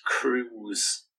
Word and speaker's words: Krug: And Krug said, Oh, it's actually pronounced Cruz. – Like, wow. – Like Penelope Cruz.